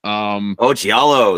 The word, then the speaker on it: Giallo: Giallo, yes.